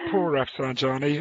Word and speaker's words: Pour Rafsenjani: Mandi Poor Rafsenjani, who is fantastic.